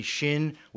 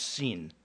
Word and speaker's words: shin: The B becomes a shin, which sometimes is pronounced sin, S-I-N, all right?